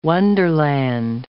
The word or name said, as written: wonderland